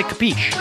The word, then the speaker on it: capiche: Capiche? Okay, great. Say please. capiche. Okay, thanks. No, you're supposed to say capiche.